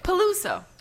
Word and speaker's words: Peluso: How do you say it? – Peluso.